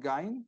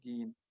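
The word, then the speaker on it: Gein: Ahora, sobre su apellido, muchas personas lo pronuncian Gain, como si fuera alemán, Ed Gain, o Ed Gain, como eh, latinizado, pero sin embargo la forma correcta de pronunciarlo es con una I larga, Ed Gain Bien,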